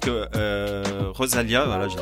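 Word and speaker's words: Rosalía: Voilà, donc vous êtes en train d'écouter Rosalia je l'ai bien prononcé Rosalia.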